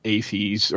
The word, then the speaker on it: AAFES: It's pronounced AFES, or it's an acronym called AAFES. We called it AFES, or the Exchange.